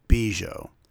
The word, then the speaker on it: Biesiot: Bob Bijou. It's spelled B-I-E-S-I-O-T.